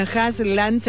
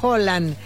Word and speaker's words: Haaland: Ya que hablan de pronunciación, Haaland se pronuncia Holland, pero ya todos le dicen Haaland, ya fuera de Escandinavia.